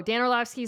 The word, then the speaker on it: Dan Orlovsky: Or the one neither Jessica or I know how to pronounce, Dan Orlovsky. – Oh, Dan Orlovsky is my colleague on NFL Live.